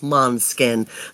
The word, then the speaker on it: Måneskin: It's spelled M-A-N-E-S-K-I-N, but pronounced Måneskin.